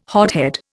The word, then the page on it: hothead: Indian